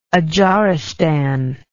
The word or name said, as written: adzharistan